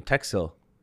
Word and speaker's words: Teksill: I think it is a nice name, Teksill. Two syllables, nice and quick.